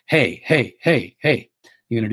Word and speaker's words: hei: We're going to use the sound power, hey, just like the food that horses and cows eat, except it's spelled H-E-I, hey.